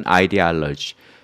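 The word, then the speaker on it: ideology: 독일어로 읽을 때는 이데올로기라고 읽는 거고요. 영어식으로 읽으면 이데올로지라고 읽는 거죠. 또는 아이디올로지. 그러면 여기 나온 이데올로지의 형용사꼴은 cul 이렇게 붙이면 뭐뭐인 거니까 ideological 그러면 이데올로기적인 이런 뜻이 되겠습니다.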